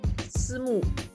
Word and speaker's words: Simu: Simu. – Simu. – Yeah, Liu. – Because it's M. It's S-I-M-I, right? – S-I-M-U.